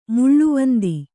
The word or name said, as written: ಮುಳ್ಳುವಂದಿ